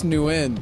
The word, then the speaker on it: Nguyen: How'd you pronounce his last name? Nguyen? Do you remember? Do you remember how you said it? Because you said it right. – I thought it was just Nguyen.